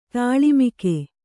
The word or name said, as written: ಧೂಳಿಮುಕ್ಕು